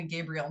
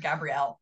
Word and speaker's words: Gabrielle: call me Gabriel instead of Gabrielle.